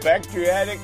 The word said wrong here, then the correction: bariatric: How do you say that? – Bariatric. – Oh, there you go. Bariatric surgery.